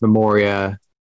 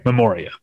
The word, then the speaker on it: Memoria: I've heard it pronounced Memoria by, I would think, people who would know. – Okay, Memoria then.